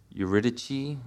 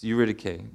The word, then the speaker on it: Eurydice: And in that movie, uh, the protagonist pronounces it Eurydice, which is not right. It's Eurydice.